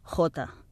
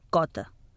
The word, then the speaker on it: jota: En alguns llocs del territori catalanoparlant es manté amb la fonètica original i l'anomenen jota, mentre que en altres s'ha convertit en cota. En estàndard s'ha imposat la pronúncia jota, que aplica a la paraula escrita les regles de lectura del català.